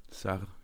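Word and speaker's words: Sarr: Ähm, deswegen bin ich umso gespannter, was du jetzt dazu sagst, nämlich Buna Sar, wenn ich das richtig ausspreche. Sar, Sar. – Sar, genau.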